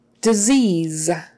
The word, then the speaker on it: disease: Um, both of those S's are pronounced with a Z sound. Disease. That EA is an E, E, E sound. Disease. Disease. Um, I'm sorry you have a dread disease. Disease. And the last E is silent.